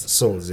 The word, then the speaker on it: Souls: Souls, yeah. – And that sounds so international. Jabu Souls. – Yeah, as in like a soul.